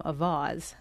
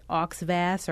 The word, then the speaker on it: Auxvasse: And, you know, that's a French-inspired name pronounced basically correctly. It's AUXVASSE. And it's funny because people assume that it's going to be pronounced incorrectly, you know, some Americanized version, Missouri version of the pronunciation. So people will ask you if they know that you're from that area, oh, how do they say that? Is it Oxvass or Oxvassy?